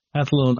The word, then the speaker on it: Athlone: founded by Aidan Bond and some colleagues in Athlone, Ireland in 2002. Is that, am I pronouncing the town right? – That's right, yes. Athlone, County Westmeath, Ireland.